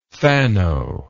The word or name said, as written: phano